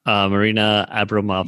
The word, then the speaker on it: Marina Abramovic: Oh, Marina Abramovic.